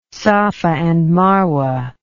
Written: safa and marwa

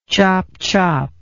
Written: chopchop

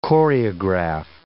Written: choreograph

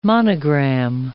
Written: monogram

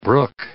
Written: brook

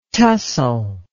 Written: tussal

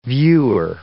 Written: viewer

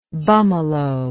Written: bummalo

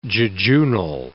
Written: jejunal

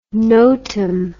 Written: notam

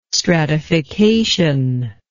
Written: stratification

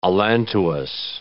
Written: allantois

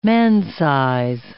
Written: man-size

